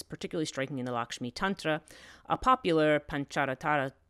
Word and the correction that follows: Pancharatra: um, uh, Panchara, Pan- Pancharatra, thank you, I'm saying it wrong, Pan- Pancharatra text, which says that she single handedly undertakes the creation of the universe with only one billionth a part of herself.